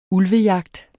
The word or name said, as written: ulvejagt